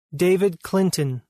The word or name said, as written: David Clinton